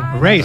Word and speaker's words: Raye: Ray. – Ray.